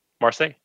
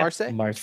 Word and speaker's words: Marseille: Marseille, yeah. – Marseille. – Is that how you say it? – That is how you say it.